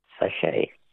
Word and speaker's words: sachet: Sachet. – A sachet. S-A-C-H-E-T. Well, they were too easy for you, so we're not asking you any more words that end with a silent T, mainly because there are no more questions in that category.